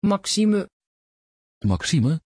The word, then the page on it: Maxime: Dutch